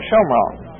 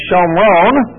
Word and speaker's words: Shomron: of Samaria, or Shomron. And so it's called, in biblical terminology, Shomron, or Samaria. We call it Samaria in Greek, and we took the Greek pronunciation, so we call it Samaria instead of Shomron.